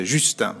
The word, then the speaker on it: Justin: Sì, Justin Taylor, Justin. Perché ha un nome inglese, ma infatti è totalmente francese, non si dice Justin, ma si dice Justin.